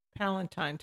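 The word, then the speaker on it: Palestine: Is it Palatine, Texas? Palatine. I'm sorry, Gene. – Palatine, Texas.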